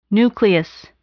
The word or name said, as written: nucleus